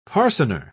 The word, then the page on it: parcener: British English